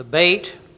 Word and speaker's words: bet: The bet, pronounced Beth in English, symbolizes a house.